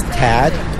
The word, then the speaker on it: Tad: My name is Tad. Tad Hara. – How do you spell that, Tad? – T-A-D-H-A-R-A.